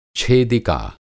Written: छेदिका

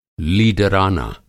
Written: लीडराना

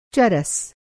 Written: चरस

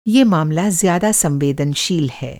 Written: संवेदनशील

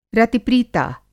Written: रति-प्रीता

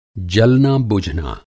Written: जलना-बुझना

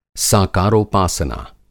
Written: साकारोपासना

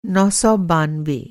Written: नौ सौ बानवे

